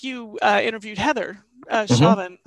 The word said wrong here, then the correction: Chauvin: mm-hmm. or yes. Coven, is how you pronounce her last name.